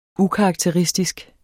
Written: ukarakteristisk

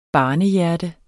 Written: barnehjerte

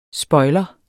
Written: spoiler

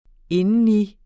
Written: indeni

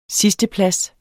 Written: sidsteplads

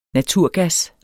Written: naturgas

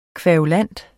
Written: kværulant

